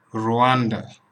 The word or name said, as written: Rwanda